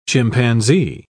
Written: chimpanzee

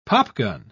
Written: popgun

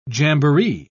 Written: jamboree

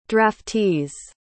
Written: draftees

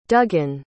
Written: dugan

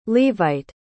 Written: levite

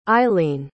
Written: isleen

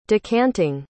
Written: decanting

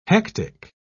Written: hectic

